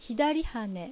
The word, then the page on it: Hidariha: Japanese